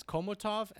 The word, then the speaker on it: Chomutov: I mean, the name of my town is Komutov, and that's Chomutov. You say it like a K.